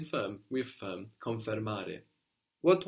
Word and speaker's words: confermare: In Italian is confermare. Now this is spelled with an I in English, confirm. But in Italian it becomes an E, which consequently makes it sound more like the English, no? Confermare.